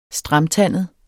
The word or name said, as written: STRAMTANDET